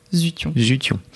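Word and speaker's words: Zuttion: Alors comme ça qu'on dit. Oui, Quentin Zution. – Je veux pas dire je veux pas écorcher. – Zution. Oui.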